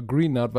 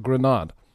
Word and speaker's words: Greenard: Ich sage mal Greenard, weil er mit zwei E geschrieben hat, aber Grenard.